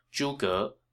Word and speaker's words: Zhuge: Note that his last name is two syllables, Zhuge, rather than the more common one-syllable Chinese last name.